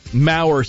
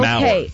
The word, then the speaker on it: Maurer: Stan and Donna Moore. Maurer. How do you think you pronounce that? Stan and Donna Maurer? – Maurer sounds good, like the former Minnesota Twins catcher. – Okay. Maurer.